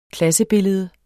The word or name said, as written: klassebillede